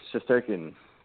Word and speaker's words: Shesterkin: Sheshkarin? Sheshkorin? – Shesterkin. – Shesterkin? Yeah, whatever.